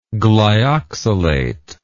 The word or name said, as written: glyoxylate